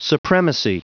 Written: supremacy

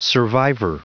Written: survivor

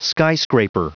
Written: skyscraper

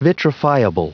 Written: vitrifiable

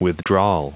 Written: withdrawal

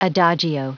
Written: adagio

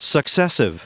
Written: successive

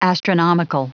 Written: astronomical

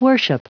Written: worship

